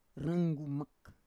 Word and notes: ranggumak: Roon

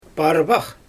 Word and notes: parbax: Xyzyl

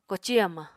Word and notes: koci hamañ: Apatani

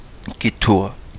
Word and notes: gitua: kapingamarangi

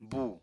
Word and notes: bù: apatani

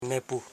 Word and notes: nepu: ne.pu

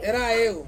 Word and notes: ra yu: Ansus

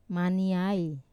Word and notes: maniai: Wamesa

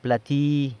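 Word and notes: plati: San Lucas Quiaviní Zapotec

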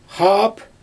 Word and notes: xaap: Xyzyl

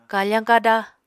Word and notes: kafañ kada: apatani

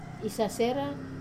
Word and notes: sasera: Wamesa

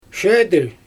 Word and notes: ʃedølʲ: Xyzyl

